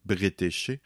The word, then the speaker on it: Bretécher: D'ailleurs, je prononce probablement très mal son nom. Il me semble qu'habituellement, on le prononce Bretécher.